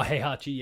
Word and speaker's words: Heihachi: I have no care for Hiachi Mishima from Tekken. I don't even remember how to pronounce his name. – Oh, Heihachi, yeah, right. I think he's like Heihachi.